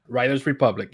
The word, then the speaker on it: Riders Republic: ¿Lo dije bien? – Yes, Riders Republic.